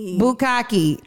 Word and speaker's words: Bukkake: It spelled like with a K, but it spelled like bukake. That's how I didn't know how to say it. But it's what is it? Bukkake? – Bukkake.